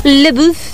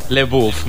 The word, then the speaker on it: LaBeouf: LaBeouf. – LaBeouf, molt bé. Doncs Shia LaBeouf. – M'ho he inventat, una... – Pot ser, pot ser. Que és un actor nord-americà conegut pels seus... LaBeouf, sí, doncs LaBeouf.